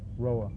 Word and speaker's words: Roha: Robert Roa. – How do you spell that last name? – Roa, R-O-H-A. – R-O-H-A. R-O-H-A.